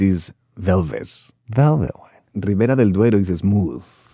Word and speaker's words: del Duero: del Duero.